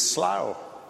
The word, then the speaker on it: Slough: Because I have the Oxford BBC Guide to Pronunciation, which every preacher has on his desk, and it is Slough.